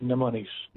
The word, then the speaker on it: mnemonics: The M is silent. Mnemonics. – Mnemonics.